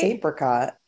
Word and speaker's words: apricot: Is it apricot or apricot? It's apricot, right? – It's apricot. Yeah, with a... Yeah, the long A.